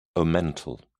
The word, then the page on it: omental: British English